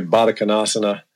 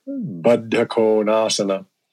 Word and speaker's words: Baddha Konasana: And then, you know, some of the asana names, like people say Baddha Konasana instead of Baddha Konasana, which is the way you're supposed to pronounce it.